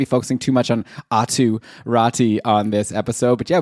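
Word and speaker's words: Aatu Räty: So Atu Rati, I think, is the realistic pronunciation.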